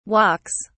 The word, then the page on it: walks: British English